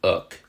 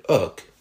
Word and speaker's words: oc: The word oc was used in the south and is still spelled OC in modern Occitan, though apparently the C is silent now.